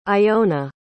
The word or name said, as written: iona